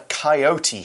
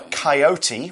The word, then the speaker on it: coyote: And then we see in the final piece of description that Pat and Laura jump as they hear a coyote howl. Now, a coyote, which can also be pronounced coyote, both are correct. Apparently, it's just where in the country you come from.